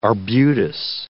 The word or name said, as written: arbutus